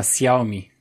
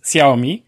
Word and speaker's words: Xiaomi: w e, Czekaj, bo ostatnio Weronika Troszczyńska wrzuciła kurs na Instagrama wymawiania chińskiego i tam było, jak się wymawia, to jest chyba Xiaomi prawidłowo, ale mogę się dalej mylić. Więc Xiaomi.